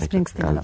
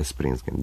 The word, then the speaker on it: Springsteen: Ideja za naslov koncerta zapravo došla je od pesme, jedne rock pesme, Bruce Springsteen, ako sam dobro izgovorio, to je Born in USA, je li tako? – Bruce Springsteen. – Da, Springsteen, da, nisam, možda dobro.